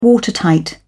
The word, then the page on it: watertight: British English